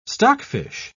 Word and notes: stockfish: British English

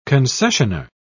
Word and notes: concessioner: British English

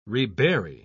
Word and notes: rebury: British English

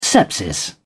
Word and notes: sepsis: British English

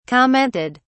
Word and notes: commented: British English

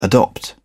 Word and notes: adopt: British English

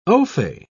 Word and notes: ofay: British English

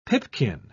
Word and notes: pipkin: British English

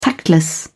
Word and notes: tactless: British English

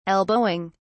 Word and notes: elbowing: British English

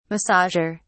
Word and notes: massager: British English

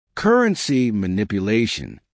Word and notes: currency manipulation: American English

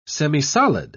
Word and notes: semisolid: British English

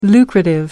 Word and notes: lucrative: American English

